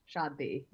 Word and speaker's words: Shaadi: Shaadi, yeah, Shaadi. – How do you say that word and what does it mean? – Shaadi is Hindi for wedding.